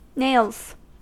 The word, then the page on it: nails: American English